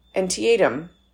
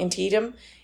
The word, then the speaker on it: Antietam: And on this date in history, in 1862, more than 3,600 men were killed in the Civil War Battle of Antietam. I know I said that wrong. Antietam in Maryland.